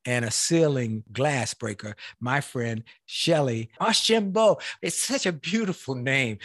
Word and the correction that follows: Archambeau: Shellye, did I say it correctly? You did really well. Ashimbo. Ashimbo, that's right. Ashimbo.